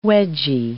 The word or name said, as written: wedgie